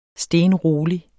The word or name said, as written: stenrolig